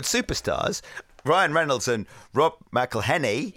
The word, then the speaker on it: McElhenney: It's McElhenney. What ways to massacre and mispronounce it, there are many. – McElhenney. – It's McElhenney.